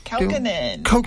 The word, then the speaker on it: Kaukonen: We just heard from Jorma Kaukonen. – Kaukonen. – I've always said that wrong.